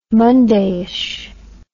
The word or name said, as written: mondayish